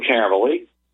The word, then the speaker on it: Caverly: But write to me, Luke Caverly. Again, that last name is spelled C-A-V-E-R-L-Y.